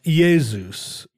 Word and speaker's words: Iesus: Well, like so, for instance, the name of Jesus was pronounced Iesus in Greek and spelled with an I. Iesus is the Greek pronunciation.